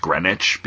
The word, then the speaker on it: Greenwich: Like, if I wasn't from New York and had never been around the city, like, I wouldn't know that Greenwich is pronounced Greenwich because it's spelled right.